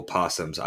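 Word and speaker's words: opossum: Why does it have a silent O in front of it? Like, oh, possums. I always, anytime I read that word, I'm like, oh, it's possum.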